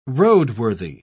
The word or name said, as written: roadworthy